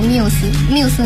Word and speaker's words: Muse: 这个是因为吧，我们考虑到怕大家读起来太过辛苦，所以就是体谅大家，所以呢，我们就把它读音还是读 Muse 女神的那个 Muse。 – 好，我的名字都这么为他们的粉丝着想，难道不是因为团长懒吗？所以呢，我们这个小伙伴们纠结到底读 Muses 还是 Muse 的啊，就可以跟着我们的团长大人一起来读 Muse 了，是不是？ – 对，Love Muse。